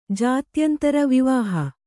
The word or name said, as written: ಜಾತ್ಯಂತರ ವಿವಾಹ